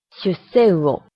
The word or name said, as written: shusseuo